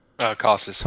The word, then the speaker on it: Casas: How do you say it? Cases? Casas? I don't even know. – Casas. – Casas, yeah. He was already in the middle of that streak in the second half.